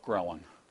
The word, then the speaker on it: ghrelin: ghrelin.